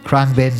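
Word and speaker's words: Khruangbin: How do you say him? – Is it Krangbin? – Krangbin, that's the one.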